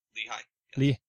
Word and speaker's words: Lehigh: It's Lehigh.